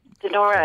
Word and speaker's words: Donora: Donora, uh, yeah.